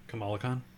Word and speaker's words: Kamala Khan: So it has her and Kamala Khan, Miss Marvel, in it. – Kamala Khan? Yeah. I mean, I like Kamala Khan, so. – What did I say? – I think you're saying it right.